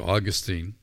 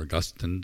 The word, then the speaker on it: Augustine: Oftentimes it's attributed to Augustine, or Augustine, depending on your Western or English pronunciation.